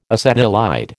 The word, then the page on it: acetylide: American English